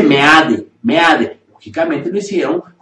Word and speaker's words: Meade: Si se piensa dedicar el voto a este personaje, es por, por Meade, no es Smith. – No es Smith. No he encontrado una sola regla dentro del idioma español que nos diga que eso tiene que ser pronunciado como mit, Eso es mentira. – Se debe leer Meade.